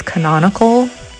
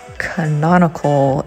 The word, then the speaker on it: canonical: It's pronounced as canonical. Say with me, canonical. It is spelled C-A-N-O-N-I-C-A-L.